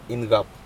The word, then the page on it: incaP: Aneityum